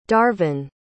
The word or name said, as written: darvin